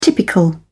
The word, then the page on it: typical: British English